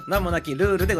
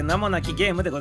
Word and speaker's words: 名もなき試合: 名もなきルールでございます。名もなき試合と書いてね、あ、違う、名もなき試合と書いて名もなきゲームでございますからね、皆様。